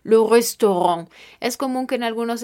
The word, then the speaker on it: restaurant: Este sonido se pronunciará como una O normal, como en español. Estamos hablando de algunos ejemplos de este sonido, como la palabra le restaurant, le restaurant,